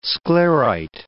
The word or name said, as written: sclerite